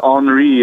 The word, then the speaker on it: Henri: Think of it as Henri. It rhymes with ennui.